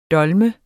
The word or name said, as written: dolmer